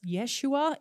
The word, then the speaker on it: Yeshua: And the name Jesus, probably more accurately pronounced Yeshua in Hebrew, is really similar to the name Joshua, which means Savior.